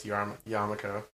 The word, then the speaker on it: yarmulke: We can always use Yiddish, and then it's yarmulke. – Yarmulk? – Yarmulk. It's kippah in Hebrew. – Yeah. Excellent. – I'll stress on that second to last syllable.